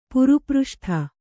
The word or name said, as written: ಪುರುಪೃಷ್ಠ